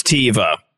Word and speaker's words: Teva: How do you pronounce Teva? – Teva. – Teva, see? Teva. He's like, what are you talking about, Jimbo? It's Teva? Teva, baby.